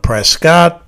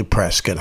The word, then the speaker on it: Prescott: Some call it Prescott, but it's actually Prescott. I know it's spelled Prescott. I know.